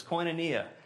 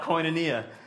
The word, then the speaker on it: koinonia: And the word used here in the Bible to describe this fellowship is koinonia. I don't know if that's how you pronounce it in Greek, but that's how Southerners pronounce it. We call it koinonia.